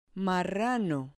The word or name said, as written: marrano